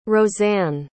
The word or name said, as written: rosann